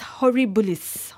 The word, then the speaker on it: horribilis: As Queen Elizabeth put it back in 1992, it's been an annus horribilis. Horribilis, right? That's how you pronounce it in Latin, meaning a horrible year.